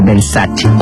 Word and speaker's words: Versace: Por ejemplo, Versace, cuando la marca Versace es italiana, o sea, tiene que pronunciar Versace.